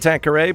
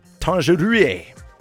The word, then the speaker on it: Tanqueray: You can pronounce it like the gin Tanqueray, but it's Tanqueray, as it's spelt.